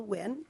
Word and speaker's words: Nguyen: My name is Jill Wynn. That's spelled N-G-U-Y-E-N, and I'm an attorney at the Department of Natural Resources.